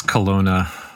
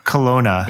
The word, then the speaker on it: Kelowna: Up in Kelowna, am I pronouncing that right, Matthew? – It's Kelowna. – Kelowna, yeah.